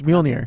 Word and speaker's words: Mjolnir: Yeah, but do you pronounce the M? – Yeah. – I don't know. – It's Mjolnir.